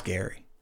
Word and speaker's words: Geary: And it's not even worth pointing out that his name is actually pronounced Gary.